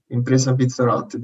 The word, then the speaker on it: Impresa Pizzarotti: So you work for Impresa, and this is where my Italian is not good, Impresa Pizzarotti. Now you're going to pronounce that properly for me. – That's perfect. Impresa Pizzarotti, that's perfect.